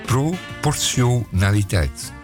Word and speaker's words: proportionaliteit: proportionaliteit. En dat is al niet eens goed. Het is proport, dat gaat over proportie. Het is proportionaliteit.